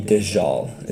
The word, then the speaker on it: Dajjal: I think you pronounce it the Dajjal, is how they say it.